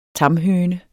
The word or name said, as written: tamhøne